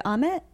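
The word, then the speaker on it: Ahmet: Our network interviewed Amit.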